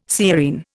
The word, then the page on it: sciurine: Indian